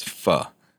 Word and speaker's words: pho: The way you pronounce the Vietnamese soup is pho.